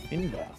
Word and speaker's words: Fionnbharr: F-I-N? – F-I-O-N-N-B-H-A-R-R. Not even joking. That's... – Finbar.